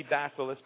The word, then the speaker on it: Bacillus: but Bacillus, I believe it's called. It might be Bacillus, but it's spelled B-A-C-I-L-L-U-S.